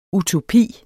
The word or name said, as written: Utopien